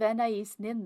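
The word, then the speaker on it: Anaïs Nin: Nice use of Anais Nin, though, and properly pronounced, so I'll give you double points for that.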